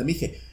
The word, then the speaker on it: Mixe: Ah, no, M-I-X-E, e, Mixe. Pero se pronuncia Mije.